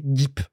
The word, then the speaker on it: GEEP: Ils ont prononcé tout de suite, ils ont précisé tout de suite, ça se prononce G-E-E-P, Gip. Donc, comme ça, il n'y a pas le débat, tu vois, comme GIF ou GIF, c'est bon, là, c'est Gip.